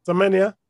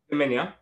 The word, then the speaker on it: Semenya: Semenya. – Semenya.